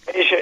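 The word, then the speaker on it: A'ja: Asia, yeah, Asia Wilson, yeah.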